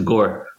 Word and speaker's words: Gore: It's Gore. – Gore. So it's like Al Gore.